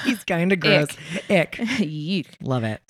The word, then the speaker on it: Icke: And in modern day, the whole lizard people theory has been popularized by a guy named David Icke. – Great name. That is strong name. – Yeah. That's how I was like, is this how you pronounce it? That's how Google told me to pronounce it.